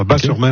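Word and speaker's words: Batz-sur-Mer: On prononce Bas, le T ouais, ne se prononce pas. Bas-sur-Mer.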